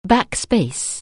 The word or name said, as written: backspace